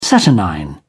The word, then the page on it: saturnine: British English